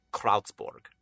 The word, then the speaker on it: Kreuzberg: And a lovely young woman from Hamburg explained to me that what I've been referring to as the Kreuzberg neighborhood of Berlin is actually pronounced Kreuzberg.